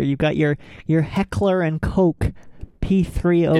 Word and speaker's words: Koch: Okay, so there are two ways to pronounce it. – Okay. – Which is Heckler and Koch, as you said. – Nice. – And Heckler und Koch, which is the German pronunciation.